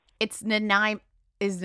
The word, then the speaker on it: Nanaimo: It's Nanaimo. Is Nanaimo, am I saying it correctly now? Nanaimo.